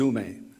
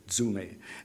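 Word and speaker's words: zume (ζύμη): It's pronounced zume, D-Z-O-O, zume.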